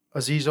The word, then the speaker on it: Azeez: Azeez Ojulari, look up what happened to him at the draft, please.